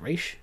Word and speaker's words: Ra's: Um, without Denny, we wouldn't have Ra's al Ghul or Ra's al Ghul, however you pronounce it. I think Danny Denny pronounced it Raish, so I'll, I'll pronounce it Raish.